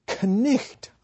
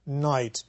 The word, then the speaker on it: knight: If we pronounced what we write, it would sound like knicht, I suppose, something like that. But we pronounce it knight.